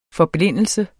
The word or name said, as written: forblindelse